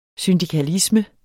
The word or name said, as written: syndikalisme